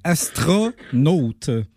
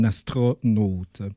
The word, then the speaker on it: astronaute: Et sinon, ben pour on l'apprendra tous ensemble, c'est bien astronaute. Donc, c'est le premier O, c'est un O ouvert. Le deuxième O, c'est un O fermé. Donc, c'est vraiment un astronaute qu'on devrait dire pour la bonne prononciation.